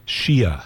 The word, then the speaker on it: Xiuh: That's Miss Letitia Shia. Her last name is spelled X-I-U-H, but I believe it's pronounced Shia.